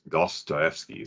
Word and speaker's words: Dostoevsky: have really gotten into Dostoevsky.